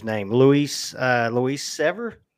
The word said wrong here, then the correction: Devers: the minor league pitcher of the year last year. – Devers. – Right, Luis Devers.